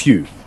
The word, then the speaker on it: Pugh: Pugh. Florence Pugh. I think the Got P it. Is silent. So I think it's Florence Pugh.